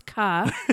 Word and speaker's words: Carr: They're like, yep, Carr or is it K? And I'm like, no, I'm pretty sure my name's Carr.